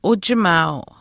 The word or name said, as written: uchimaau